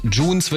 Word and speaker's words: Junz: K-O-Z-A-R-T-H. Also ausgesprochen mit einem härteren S und Z lautmäßig. Und Junes wird geschrieben J-U-N-Z.